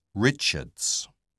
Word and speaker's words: Richards: Richards. – Could you spell that, please? – Yes, it's RICHARDS.